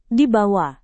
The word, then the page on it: dibawa: indonesian